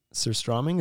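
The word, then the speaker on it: surströmming: We've got some contractors coming in who have purchased some surstromming. Is that how you say it? – Yeah.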